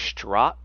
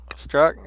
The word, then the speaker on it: Strzok: Peter, I think it is Strzok. – Strzok, yeah. – Yeah, like the Z is silent.